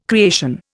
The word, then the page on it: creation: Indian